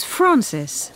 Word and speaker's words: Frances: T-A-Y-L-O-R. – T-A-Y-L-O-R. – Yes. – And your first name? – It's Francis. – F-R-A-N-C. Is it I-S? – No, E. E as in England. F-R-A-N-C-E-S.